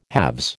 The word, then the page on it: halves: American English